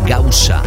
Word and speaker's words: Gausa: Siempre búscame como arroba David Gausa, G-A-U-S-A.